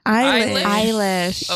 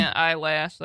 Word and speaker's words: Eilish: Eilish. – Okay, yes. – I saw some people saying eyelash, like, trying to be funny.